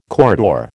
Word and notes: corridor: American English

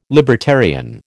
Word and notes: libertarian: American English

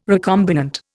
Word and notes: recombinant: Indian

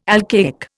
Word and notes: alcaic: Indian

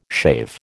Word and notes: shave: American English

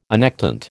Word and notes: annectent: American English

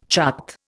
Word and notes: chart: Indian

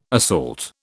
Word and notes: assault: British English

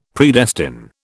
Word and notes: Predestine: British English

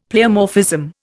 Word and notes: pleomorphism: Indian

